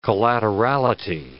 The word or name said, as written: collaterality